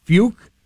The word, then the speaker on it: Fucke: I believe it's pronounced Fuke. However, it is spelled F-U-C-K-E.